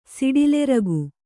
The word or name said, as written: ಸಿಡಿಲೆರಗು